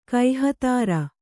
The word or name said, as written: ಕೈಹತಾರ